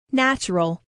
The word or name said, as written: natural